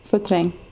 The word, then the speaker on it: Vertrank: Can you say it? Because I'm not sure, I think I'm butchering it. – Vertrank. – Yes. – That sounded the same.